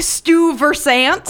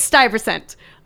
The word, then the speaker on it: Stuyvesant: And no one knows how to say it because his name looks like, yes, when you say it, it looks like Stuyvesant, mm-hmm, but it's Stuyvesant.